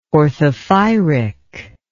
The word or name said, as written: orthophyric